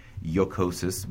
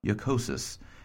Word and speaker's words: jocosus: Jocosus means to be funny, jocosus. And of course, in Latin, it begins with an I. In medieval Latin, you're going to see that as a J, but it's pronounced more like a Y, right?